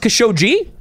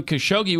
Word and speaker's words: Khashoggi: Well, I've always thought it was Khashoggi because the Khashoggis have been in the news before, back in the 80s. – For a long time, yeah. – Adnan Khashoggi was a big arms dealer. – And he was part of the Iran-Contra scandal, right? Yeah. He was mentioned in those hearings. – And his name was, the name was always pronounced Khashoggi then, so I just thought it was that. – It probably is.